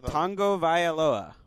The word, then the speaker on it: Tagovailoa: How do you pronounce his last name again? What did we come down with? Tagovailoa. – Right? Nice. All right, I'm going to go with Tua for now.